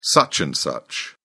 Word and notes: such and such: British English

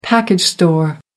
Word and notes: package store: American English

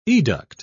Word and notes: educt: British English

